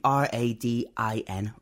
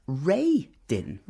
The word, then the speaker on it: Radin: because I also read his name R A D I N Radin. – Uh-huh. I also do the same thing, but it's actually pronounced Ray Din.